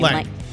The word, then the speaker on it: Lange: And it was basically after she divorced her husband, Mutt Lange. I think that's how you say his name. – Lange. – Lang. Okay. Yeah.